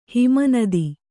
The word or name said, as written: ಹಿಮನದಿ